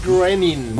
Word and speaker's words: Groening: ¿Lo dije bien? ¿Cómo? – Matt Groening. – Groening, muchas gracias.